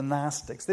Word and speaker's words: Gnostics: Now, probably the best known group of these kind of false teachers were called the Gnostics, spelled with a letter G. It's a silent G, but it's called the Gnostics.